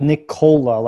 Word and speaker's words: Nikola: Yeah, you were saying like Nikola, like Nikola, Coca-Cola.